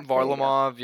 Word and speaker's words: Varlamov: Varlamov, yeah. I mean, people go back and forth, but the goalies were definitely...